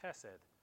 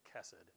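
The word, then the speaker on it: Chesed (חסד): I actually bumped into I think she'll be a student of mine next year, if I remember right, at Blackhawk, whose name is Chesed, except they don't pronounce it that way. They pronounce it Chesed, C-H-E-S-E-D. It's a Hebrew word. It's a great Hebrew word that really is pronounced with a fricative.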